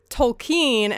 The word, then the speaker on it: Tolkien: And I make sure to say Tolkien there because the movie makes a deliberate, you know, point to say it's pronounced Tolkien, you know, not Tolkien or Tolkien or whatever.